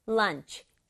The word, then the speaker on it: lunch: 우리말은 두 박자인데 영어는 lunch, 치 이렇게 하지 않고 lunch 한 박자로 발음해요.